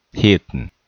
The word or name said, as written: Hilton